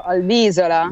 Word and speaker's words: Albisola: Ma perché Albisola si legge con la S di sola e non di isola? È vero, Albisola.